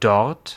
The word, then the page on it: dort: /dɔʁt/; [dɔɐ̯t]